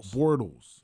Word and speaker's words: Bortles: Bortles.